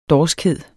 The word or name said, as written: dorskhed